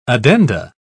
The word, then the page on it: addenda: British English